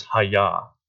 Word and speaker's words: haya: So this phrase, preserve us alive, or for our survival, and the Hebrew is haya. Like, that's how it's pronounced.